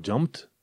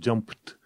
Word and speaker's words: jumped: Uite la jumped, nu îi zici uh, jumped, jumped, uh, e jumped. Edu are în cuvântul respectiv se pronunță ca T.